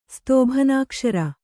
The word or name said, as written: ಸ್ತೋಭನಾಕ್ಷರ